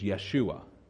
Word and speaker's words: Yeshua: I believe pronunciation is Yeshua. So it's Yeshua in Hebrew.